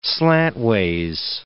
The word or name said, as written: slantways